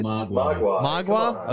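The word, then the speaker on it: Mogwai: Magua? Is that it? – Magua.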